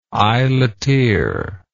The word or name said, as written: eyeleteer